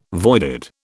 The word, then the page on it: voided: British English